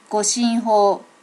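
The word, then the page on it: goshinho: native Japanese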